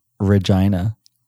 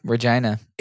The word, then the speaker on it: Regina: I lived in the capital of Saskatchewan, which is unfortunately pronounced Regina. – Yep, Regina.